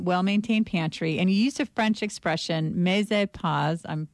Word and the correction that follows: mise en place: I speak more Spanish. I don't speak French. – I will give you the French pronunciation. – Oh, please do. – It's mise en place.